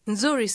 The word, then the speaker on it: nzuri: This word begins with a fast NZ sound, as at the end of the English word bronze. Listen and repeat again. – Nzuri.